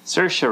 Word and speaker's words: Saoirse: or Shaori Ronan. – Saoirse Ronan. – How do you say it? Saoirse Ronan. – Saoirse. – Rhymes with inertia.